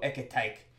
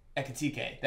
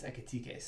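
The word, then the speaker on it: Ekitiké: Hugo Ekitike. I did not say that correctly, but he's a young kid. – Ekitike? – Ekitike. That's what it is. Ekitike.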